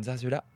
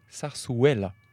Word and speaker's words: zarzuela: Alors, je tiens à dire d'abord que nous avons toutes mal prononcé parce que les deux Z se prononcent S et le U se prononce Oué. Donc on dirait plutôt zarzuela.